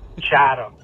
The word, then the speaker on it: Chatham: Just wanted to correct you on the pronunciation of Chatham, Ontario. – Chatham. – Nobody cares. – Not Chatham. Chatham.